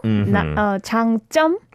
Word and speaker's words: 장점: She pronounced it like 장점, not 장점. – Because when it's spelt out, right, Changjom, you might think that's right, but when we read it, it's not like that, right? – Yeah. You put little tense on the second syllable, Changjom. – Changjom. So it's almost like a sang or double jiot sound.